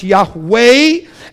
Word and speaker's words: Yahweh: Of course, you wouldn't have said that in Hebrew. That's not a Hebrew word. But in Hebrew, it would be pronounced Yahweh.